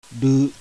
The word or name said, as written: RU